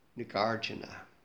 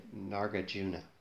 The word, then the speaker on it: Nagarjuna: So, the person who turned me on to this sutta was a fellow by the name of Nagarjuna, often said Nagarjuna. It's actually the correct way to pronounce it is somewhere between those two.